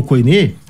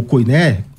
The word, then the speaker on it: koiné: koiné ou koiné, dependendo de como você quiser pronunciar, ah, isso não é verdade.